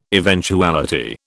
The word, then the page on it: eventuality: British English